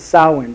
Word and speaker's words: Samhain: Witches celebrate Halloween as the festival of Samhain.